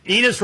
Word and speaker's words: Enos: Enos rhymes with penis.